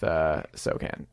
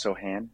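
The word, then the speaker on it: Sochan: Well, how does Victor fit with Sokan? Why am I saying Sokan? – Because it's spelled like that. – Yeah. – But it's pronounced Sohan.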